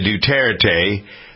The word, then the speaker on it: Duterte: Meanwhile, speaking of bluffing, the president of the Philippines, Mr. Duterte, is that how you pronounce the name? Duterte? – Sure. Yeah.